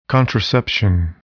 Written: contraception

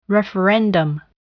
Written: referendum

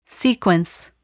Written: sequence

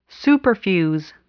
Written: superfuse

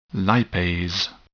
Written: lipase

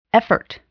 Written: effort

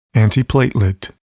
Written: antiplatelet

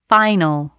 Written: final

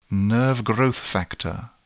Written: nerve growth factor

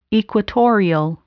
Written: equatorial

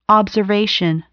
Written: observation

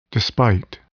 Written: despite

